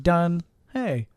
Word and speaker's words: Donehey: It's the word done, like I'm done, D-O-N-E, okay. And then hey, H-E-Y. – Okay. – But it's not pronounced hey, it's pronounced he. So right. It's confusing. But think done, hey. Yeah. I'm done, hey.